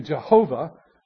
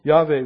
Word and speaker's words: Yahweh: It is sometimes transliterated Yahweh into Jehovah, which is really, there are no J's in Hebrew, so that's really a mispronunciation, but Yahweh would be the correct pronunciation.